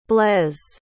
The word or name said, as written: blais